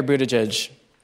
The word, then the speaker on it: Buttigieg: Mayor Buttigieg, did I pronounce it right? – You did. Well done.